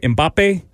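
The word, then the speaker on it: Mbappé: Am I saying that right? Mbappe? Sure, he's French. – Yeah, That's right.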